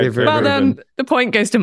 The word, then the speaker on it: Dave Rubin: His name rhymes with Maeve Boobin. His first name is Dave.